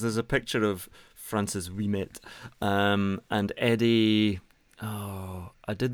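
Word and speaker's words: Ouimet: See, that's good French pronunciation, but the Americans called him Wimet because they just don't know what they're doing.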